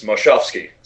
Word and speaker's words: Moshovsky: Well, you may not know this, but my last name is Moshovsky, so... – Oh, so it rhymes.